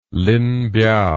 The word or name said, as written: linbiao